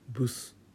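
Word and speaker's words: Bus: 乗り物のバスはどうでしたかはいブスと読むんですね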